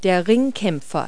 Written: wrestler

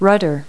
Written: rudder

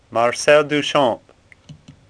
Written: Duchamp

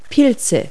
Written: fungi

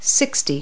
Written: sixty

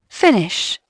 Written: fins

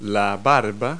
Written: silk

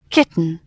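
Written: kitten

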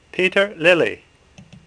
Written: Lely

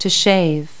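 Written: shave

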